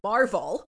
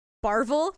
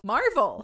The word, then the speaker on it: Marvel: You're the one who doesn't understand Marvel. – Barvel? – Marvel. – Barvel? – Marvel. – You said Barvel. – Marvel. You can't even say the name right.